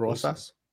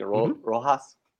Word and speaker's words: Rosas: You said it right. Raul Rosas. – Rosas, right? Rosas, right? You know, I keep saying Rojas, because that's like what's familiar to my tongue, Rojas.